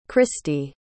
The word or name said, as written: christi